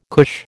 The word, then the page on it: cush: American English